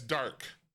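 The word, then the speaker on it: Darke: I think it's Dark, but I want to make sure I say it right, or it could be Drake. No, yeah, it's Dark. It's D-A-R-K-E.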